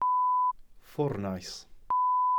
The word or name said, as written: ffwrnais